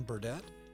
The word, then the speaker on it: Burdett: Yes, it is John Burdett.